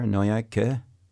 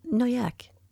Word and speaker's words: Noaillac: do you you do a thing at the end there? Noyac. – Noyac. – Yeah, but you said. Sometimes you guys hang on to things. – We do. – And and c'est normal. – Yeah, no, I understand. – Yeah. – But it's almost like you add another syllable.